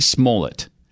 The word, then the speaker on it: Smollett: Is it Smollett? Yeah, Jussie Smollett.